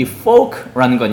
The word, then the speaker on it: folk: 이 folk라는 건요, 오우 발음하셔야 되고 발음 잘못하면 아주 큰일 납니다. 어쨌든 오우 발음하셔서 folk, L 발음하지 마세요. 그냥 folk 하시면 됩니다.